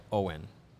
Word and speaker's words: Owyn: We're also not only brought to you by PointsBet, but also by Owen. And no, it's not Owen Power. It is spelled O-W-Y-N.